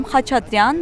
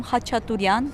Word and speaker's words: Khachaturian: En arménien, on dit Aram Khachaturian, mais les étrangers disent Aram Khachaturian ou bien Khachaturian.